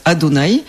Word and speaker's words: Adonai: Et en fait, dans les Bibles hébraïques, il y a des notes en marche qui proposent de prononcer à la place Adonai.